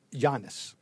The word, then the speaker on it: Giannis: Giannis. The G is pronounced as a Y.